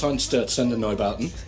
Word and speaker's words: Einstürzenden Neubauten: Blixer Bargeld from Einstürzenden Neubauten, who's in the Bad Seeds as well. – Oh, my God, you pronounced it right. – I can pronounce Einstürzenden Neubauten.